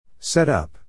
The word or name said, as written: set up